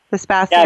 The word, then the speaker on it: Vespasian: Vespasian? – Yeah, Vespasian.